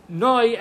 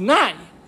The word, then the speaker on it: noi: It's ado, and then it's supposed to be noi, emphasis on the noi. It's mil ra, not mil el. The emphasis on the latter part of the word, not the beginning of the word. So far, so good. But so this noi we need to talk about. It's supposed to have a kamatz. So how do you pronounce a kamatz? So Sfaradim, or people in Israel, they pronounce the kamatz as an a. It's nai.